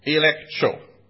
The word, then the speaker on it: elegcho: Now, the Greek word for tell him his fault is elektro. For those of you who are interested in the Greek language, it's spelled E-L-E-G-C-H-O.